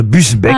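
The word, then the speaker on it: Busbecq: Augier Gillin de Busbeck, pardon, j'ai écorché son nom. – Il me semblait bien aussi. – Ça, c'est ça, oui. Est-ce que vous pouvez appeler Busbeck? – Busbeck. Augier Gislain de Busbeck. – Ah, Gislain.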